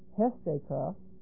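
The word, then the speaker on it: Hestaka (ἕστηκα): If you're looking at the verb, if you're interested, it's the verb estekah, has a rough reading. Hestaka, H-E-S-T, long E-K-A, which comes from histamine. Here's the source verb, H-I-S-T-E-M-I.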